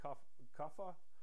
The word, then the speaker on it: kapha: If you see a bright white, like the color of the paper, it's more kapha, spelled K-A-P-H-A. Some people say kapha. But when I was in India, I had the pronunciation police after me, and they kept harping on me that the H is silent, so it's kapha.